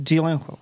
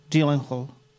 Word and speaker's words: Gyllenhaal: Meni je zanimljivo, Jake Gyllenhaal je, mislim, već poznat glumac i dugo... – Gyllenhaal. – Pa ne mogu više zvara, znači Gyllenhaal.